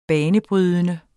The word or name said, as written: banebrydende